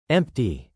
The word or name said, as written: empty